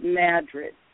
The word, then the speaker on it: Madrid: And it's pronounced Madrid, spelled like Madrid, Spain, but the locals pronounce it Madrid.